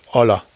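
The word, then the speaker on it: Hola: Es heißt nicht Holla, es heißt Olla. Das H spricht man nicht, Uli.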